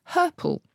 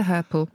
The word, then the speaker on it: hirple: Anyway, in there, you will find the word herple, spelled H-I-R-P-L-E. It does rhyme with purple.